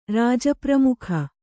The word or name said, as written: ರಾಜಪ್ರಮುಖ